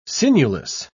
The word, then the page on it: sinewless: British English